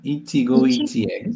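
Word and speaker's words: Ichigo ichie (一期一会): Ichigo ichie.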